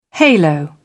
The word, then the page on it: halo: British English